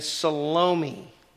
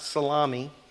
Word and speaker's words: Salome: and Salome. Okay, that's how you can pronounce that, not Salami. All right, Salome or Salome, it's also pronounced that way.